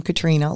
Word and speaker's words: Katrina: So I am, first of all, on social media, and I am Katrina, like the hurricane is how my name is spelled.